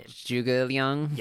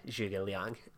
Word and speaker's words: Zhuge Liang: Zhuge Liang. – Yeah. – That's how I would pronounce it. Like the G. – Yeah, Zhuge Liang.